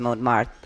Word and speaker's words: Montmartre: And then our Closing Night film, which is Montmartre. I can't pronounce it. Because I'm not French. – I think, but that was well done. – Was that well done? Okay, good.